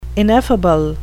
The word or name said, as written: ineffable